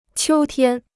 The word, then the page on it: 秋天: qiū tiān